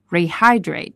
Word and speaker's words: Rehydrate: 注意是说 a post-workout routine should include， include 后面说的宾语是 fluids， fluids 是液体的意思，也就是水， fluids to rehydrate Rehydrate 在这里，其实在之前我们的晨读当中有跟大家讲过这个动词， Rehydrate 就是补水的意思，我们注意一下它的重读音节是在 Hydrate， Hydrate 这个地方， Rehydrate。